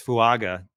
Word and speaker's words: Fuaga: Like, you know, if it's Fuaga, did I even say that right? – Yeah.